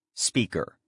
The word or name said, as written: speaker